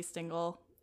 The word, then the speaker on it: Stingel: And I mean, my regular, all my art stuff is Chelsea Stingel anywhere. S-T-I-N-G-E-L is how you spell my last name.